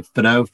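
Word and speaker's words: Fadó: And we should let our listeners know that it's spelled F-A-D-O. Pretty simple, right? – Yeah, it's spelled simply, but it's hard to kind of pronounce. A lot of people kind of get it wrong. You don't pronounce the A and the accents on the O.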